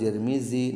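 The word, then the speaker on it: Tirmidhi: Imam Turmuzi lima atau Tirmizi bacanya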